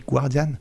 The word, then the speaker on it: Guardian: Pourquoi vous prononcez tous le U de Guardian Ah oui, c'est vrai, on dit Guardian.